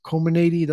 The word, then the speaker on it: Comirnaty: I think it's Comirnaty. That's how you pronounce it, from Pfizer.